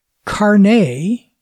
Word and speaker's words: Carnet: I personally use SimpleNote for my simple notation needs, but for most normal users, Carnet, which is the French-Canadian way of pronouncing that, spelled C-A-R-N-E-T, anglicized it would be Carnet, is a wonderful feature-rich and cross-platform application.